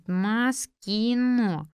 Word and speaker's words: Маскино: Мама Веры делала ударение на последнее «о». «Маскино? Есть такая организация?» «Нет». «Знаю Мосфильм». «Да нет, Маскино», — по слогам повторила она.